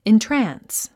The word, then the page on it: entrance: intrəns; イントラァンス